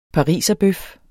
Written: pariserbøf

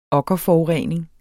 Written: okkerforurening

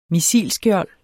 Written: missilskjold

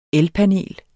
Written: ELPANELET

